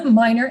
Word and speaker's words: Miner: Miner M I N E R.